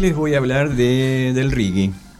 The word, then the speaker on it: Rigi: Les voy a hablar en todo caso del monte reggae. Vamos a pronunciarlo mejor, del monte reggae.